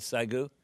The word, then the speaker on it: Saigu: Let me jump in there because we only have about 30 seconds left, and I want to know about Saigu. Can you tell me about that? – Yes. – And am I pronouncing that right, Saigu? – Saigu, 429, April 29, according to the LA, in Korean language, April 29.